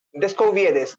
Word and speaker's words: Descubieres: Descubieres. Yo le pregunté cómo, me dijo tal cual como se, como se lee.